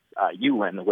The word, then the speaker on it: Ulin: It's Ulin, which is spelled U-L-I-N, and it's often mispronounced Ulin or Ullin.